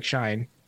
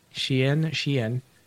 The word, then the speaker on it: Shein: It looks like Shine, but I'm told it's pronounced Shein.